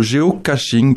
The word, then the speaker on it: géocaching: Ou plutôt le géocaching, parce qu'il n'y a pas le T, ça s'écrit G-E-O-C-A-C-H-I-N-G.